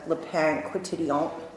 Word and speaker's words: Le Pain Quotidien: I was very hungry, so I went to the Le Pain Quotidien. – Uh-huh. Is that how you say it? – Yeah, that's exactly, because I'm French.